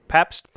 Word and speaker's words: Pabst: It's called the Pabst. Did I say that right? – Mm-hmm. Pabst Blue Pabst.